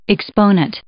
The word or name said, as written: exponent